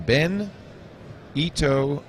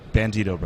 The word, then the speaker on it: Bandito: Bandito Brothers, yeah. – Bandito Brothers. Two words. Three syllables. – It's misspelled here. It's Bandito, like Frito Bandito? – Absolutely.